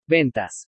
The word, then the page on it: VENTAS: spanish